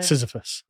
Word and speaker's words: Sisyphus: It was just, what is it, Sisyphus? How do you say his name? – Sisyphus.